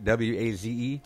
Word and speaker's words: Waze: Waze? – Yeah. – Oh, that rhymes with Blaze.